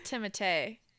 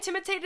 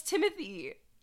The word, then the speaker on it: Timothée: I actually Googled it while you were talking about Dune to make sure, and he said it's pronounced Timothée. – Okay, good, because that's way hotter. – He said that to Teen Vogue, and it is pronounced Timothée Chalamet.